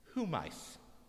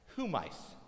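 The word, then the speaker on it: Humais (ὑμεῖς): Humais. Humais. I look at Mickey Mouse to remember how to pronounce it. Humais.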